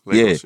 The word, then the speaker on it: Lagos: Lagos, is how you say it? – Yeah, the capital. – Lagos, yeah.